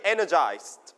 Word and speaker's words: energized: repeat after me energized นักเรียนปลายทางพร้อมกันนะครับ energized นะครับ so you put the stress on the first syllable